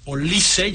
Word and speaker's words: Olise: Se pusieron a jugar Olise, ya sabemos que se dice así.